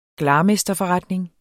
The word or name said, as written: glarmesterforretning